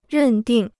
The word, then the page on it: 认定: rèn dìng